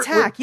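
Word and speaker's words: Taq: Yeah, uh, basic uh, we're, tack.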